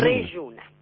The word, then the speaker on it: Reyuna: Reyuna. – ¿Cómo se escribe Reyuna? – R-E-Y-U-N-A, y después van a ver por qué se llama así, que también se los voy a contar.